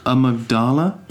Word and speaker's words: amygdala: And it's called the amygdala, I think. Mm-hmm. That's how you say it.